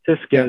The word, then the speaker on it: Tyskie: Tyskie is how it's pronounced. – Okay. – One of the simpler names because you don't have to start throwing in five consonants in the same word. – So what about the lek? Is it pronounced L-E-C-H?